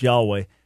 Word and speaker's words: Yahweh: the Hebrew Yud Hevav Vav He Y YHWH, which we normally pronounce Yahweh, but that name occurs almost 7,000 times.